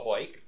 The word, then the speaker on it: Heuck: You can find me on Twitter at @THE_HOYK, the Hoyk, the phonetic pronunciation of my name.